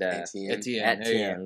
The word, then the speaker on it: Etienne: You're going to hear about Clemson's running back with Etienne and Etienne. – There we go. I'm just like Jimbo there, mispronouncing.